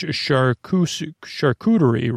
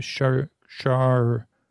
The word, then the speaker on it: charcuterie: In French, the letters C-H-A-R are pronounced Shahir. I don't know what that means. Charcuterie. I think I just got it right.